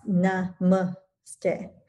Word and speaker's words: namaste: And so, and it's pronounced namaste. So it's, the emphasis is on the second syllable, not the last, as we often hear.